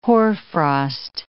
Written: hoarfrost

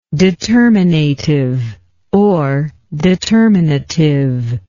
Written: determinativeness

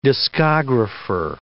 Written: discographer

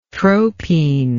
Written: tropine

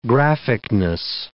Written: graphicness

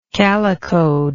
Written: calicoed

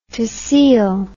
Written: tehsil

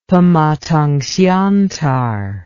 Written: Pematangsiantar

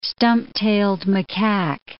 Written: stump-tailed macaque

